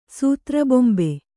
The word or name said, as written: ಸೂತ್ರಬೊಂಬೆ